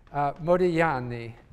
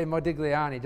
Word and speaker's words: Modigliani: Franco, and I know I was his advisee in my PhD, so I learned how to pronounce his name, Modigliani, because in Italian, you don't pronounce the G. Although I tend to say Modigliani just to be understood.